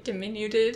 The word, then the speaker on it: diminutive: It felt very, like, diminutive. Did I say that right? Diminutive? – Diminutive.